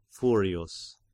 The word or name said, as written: Furius